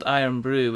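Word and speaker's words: Irn-Bru: There's a traditional Scottish drink called Iron Brew, which is spelled IRNBRU.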